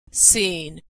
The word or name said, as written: seen